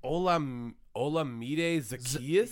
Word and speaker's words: Olamide Zaccheaus: Olamide Zaccheaus. – Yep, you just nailed it. Yeah, that's exactly how you pronounce it.